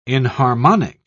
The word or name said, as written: inharmonic